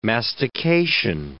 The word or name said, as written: mastication